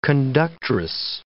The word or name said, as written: conductress